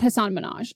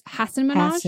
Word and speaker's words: Hasan Minhaj: It's not Hasan Minhaj. It's Hasan Minhaj.